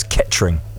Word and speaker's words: Kettering: It's Kettering. – What? – You don't pronounce the error. It's Kettering.